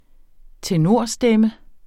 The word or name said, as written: tenorstemme